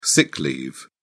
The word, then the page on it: sick leave: British English